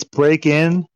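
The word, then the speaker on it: Breakin': But you guys have been mispronouncing it. It's Break In. Okay, there's no G on the end, so...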